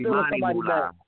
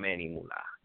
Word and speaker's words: Monty Moolah: It's Monty Moolah, not Manny Moolah. Didn't know that. – Wait a minute, so it's a Manny Moolah, then a Monty Moolah? – No. I'm saying most people pronounce it wrong. It's not Manny Moolah. That's not his name. His name is Monty Moolah. That's how he say it.